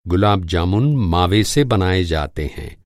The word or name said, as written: गुलाब-जामुन